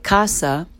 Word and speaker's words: casa: even though to you it might look like casa, and especially if you've studied Spanish, C-A-S-A in Italian is pronounced casa, because the S is surrounded by vowels. Or, as I like to nickname it, it's an S vowel sandwich, C-A-S-A, and in that case, the S sounds like Z.